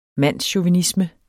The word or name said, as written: mandschauvinisme